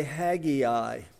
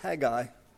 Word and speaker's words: Haggai: By the way, you're a student of the word, don't say Haggai. He wouldn't like it. It's two syllables, Haggai.